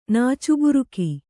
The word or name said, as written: ನಾಚುಬುರುಕಿ